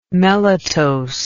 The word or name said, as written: melitose